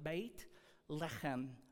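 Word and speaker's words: Beit Lechem (בית לחם): So Bethlehem is how we pronounce it, but Beit Lechem, bread.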